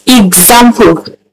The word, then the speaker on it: example: So the two infused together brings out ig and not X. So the word is pronounced example.